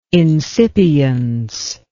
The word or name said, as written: insipience